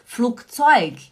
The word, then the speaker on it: Flugzeug: Flugzeug. Aí se escreve também com G, né? Flugzeug. Mas você não fala o G, você fala K. Flugzeug.